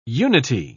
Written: unity

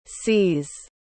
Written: cees

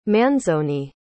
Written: Manzoni

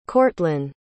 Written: courtlyn

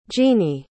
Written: jeannie

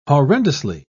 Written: horrendously

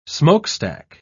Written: smokestack

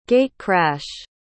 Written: gate-crash

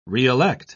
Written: reelect

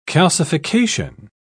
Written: calcification

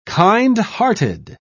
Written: kindhearted

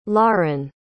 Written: laren

